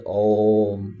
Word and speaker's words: Om: I don't think you break it. You just chant it as Om, because A and U becomes O.